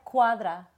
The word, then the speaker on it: Cuadra: Yeah, Cuadra.